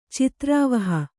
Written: ಚಿತ್ರಾವಹ

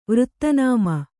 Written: ವೃತ್ತನಾಮ

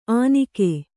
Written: ಆನಿಕೆ

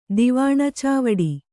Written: ದಿವಾಣಚಾವಡಿ

